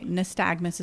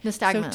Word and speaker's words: nystagmus: So, okay, nystagmus is the right way to pronounce it. – Nystagmus.